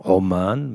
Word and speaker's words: Oman: Ansonsten vielleicht noch Oman, wenn man ein richtiger Profi ist, dann kann man sagen Oman mit so einem mit so einem Ein ausgesprochen.